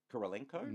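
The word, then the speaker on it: Kurylenko: Kirilenko? – Nailed it.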